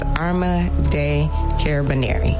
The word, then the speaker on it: Arma dei Carabinieri: Arma dei Carabinieri.